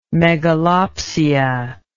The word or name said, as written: megalopsia